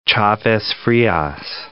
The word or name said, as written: Chavez (Frias)